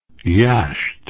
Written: yasht

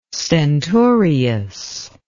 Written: stentorious

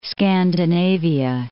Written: Scandinavia

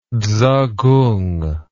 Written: tzukung

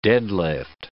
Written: deadlift

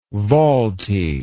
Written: volti

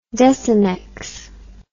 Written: desenex

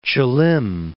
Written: Chulim

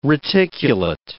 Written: reticulate

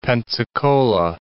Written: Pensacola

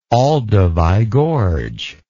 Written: Olduvai Gorge

